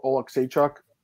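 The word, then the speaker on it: Oleksiejczuk: Middleweight division, we have Michelle Pereira against Michael Oleksychuk. I said his name right this time, finally, Marcel.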